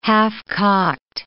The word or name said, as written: half-cocked